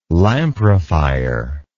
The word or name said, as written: lamprophyre